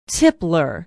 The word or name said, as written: tipler